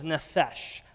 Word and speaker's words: nephesh: The Hebrew word for soul is nephesh. Let me hear you all say nephesh. Nephesh. Very good pronunciation.